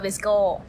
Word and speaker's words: VSCO: VSCO ใช่ใช่ VSCO เขาอ่านว่า Visco ใช่